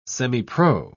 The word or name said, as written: semipro